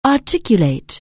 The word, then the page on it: articulate: anglais